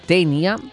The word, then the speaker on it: Taenia: Ah, bueno, hay una banda que se llama Tenia. Se escribe Taenia.